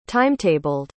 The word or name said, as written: timetabled